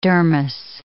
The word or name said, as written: dermis